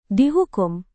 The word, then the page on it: dihukum: Indonesian